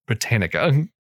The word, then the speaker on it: Britannica: Like Britannica. I'm like, no, it's Britannica.